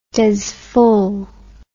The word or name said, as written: dezful